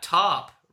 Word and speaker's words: TARP: Did you know that TARP rhymes with harp?